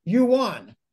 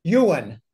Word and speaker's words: yuan: So you can online make a trade payment using the yuan. Actually, it's pronounced yuan, but I say yuan. I had some Chinese clients correct me. They said, Jim, it's yuan. Oh, I don't care. To me, it's yuan.